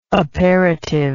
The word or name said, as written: aperitive